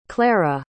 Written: claira

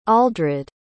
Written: aldred

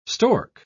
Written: stork

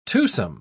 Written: twosome

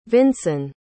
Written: vinson

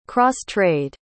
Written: cross-trade

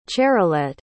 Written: charolette

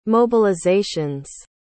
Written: mobilizations

